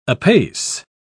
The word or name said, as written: apace